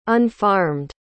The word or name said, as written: unfarmed